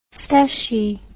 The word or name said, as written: stushie